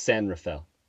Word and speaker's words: San Rafael: it's it's San Rafael. – Really? – Yes. – It says Raphael. – It's pronounced. I lived there for 20 years.